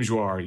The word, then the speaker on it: Jouar: Even in your the Engadget review, Miriam, is it Jouar? How do you pronounce Miriam's last name? – Miriam Jouar, yes.